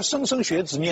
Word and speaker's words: 莘莘学子: 商末期有一个国，这个读起来非常小心，因为这个字的读音搞不清楚，莘莘学子的莘，但是这个字呢读地名的时候有的时候读成心啊，所以现在一般莘莘学子念莘，山东莘县，但是上海有个地名叫新庄，所以这个字不知道怎么念，咱们姑且把它念成莘。